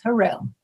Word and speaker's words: Hurrell: Yes, Harrell.